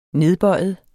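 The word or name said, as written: nedbøjet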